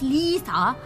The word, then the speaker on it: Liese: Her name is Lisa. Pronounce Lisa and spell L-I-E-S-E.